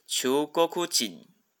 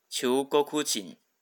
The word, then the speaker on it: 中国人: 好，中国人呢，它的重音是在哭上面。好，所以它念作求国库紧。求沟哭紧，好，重音的地方不太一样哦。